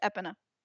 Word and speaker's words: Epona: Something that I didn't know is Epona. As a kid, I pronounced it Epona. – I always pronounced it Epona. – It's Epona. I guess.